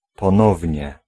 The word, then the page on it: encore: /ɑ̃.kɔʁ/; France